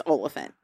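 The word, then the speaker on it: Olyphant: Well, so again, I watched a Conan clip where literally it's how do we say Timothy's name, and Timothy is like, it's Oliphant. Like, I watched over and over Oliphant. And then wrote it phonetically. – Oliphant.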